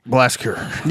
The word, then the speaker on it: Blashyrkh: Most of the lyrics focus on a realm called Blashgir. I don't know how to pronounce it. – You just did. You got it, baby. – I tried. It's spelled Blashyrkh. So Blashgir. – Yeah. Good luck. – A breath at the end.